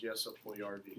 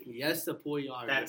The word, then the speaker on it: Puljujärvi: Jesse Puljujärvi. – Yeah, no. Puljujärvi? – Correct pronunciation is Jesse Puljujärvi. – Jesse Puljujärvi. – That's Finnish terms. In U.S., that's how you say it.